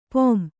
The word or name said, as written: ಪುಂ